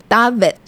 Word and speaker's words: Dafydd: 因为他的名字拼音是 D A F Y D D。那这个名字呢，我自己比较没有见过，很担心发音错误。结果 Google 告诉我是要念成 David，所以老师的名字也就是他的 first name 是 David，那姓氏呢，也就是他的 last name 就是 Fell，F E L L。